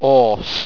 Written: orce

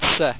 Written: tse

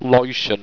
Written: loittion

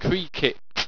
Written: kreackippt

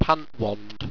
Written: pantwand

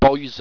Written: boisen

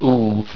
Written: uuv

